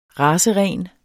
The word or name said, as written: raceren